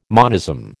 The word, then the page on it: monism: American English